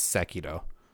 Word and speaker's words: Sekiro: and I just recently started Sekiro, I think it's called? – I've heard someone who, like, knows Japanese pronounce it as Sekiro.